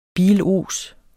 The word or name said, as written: bilos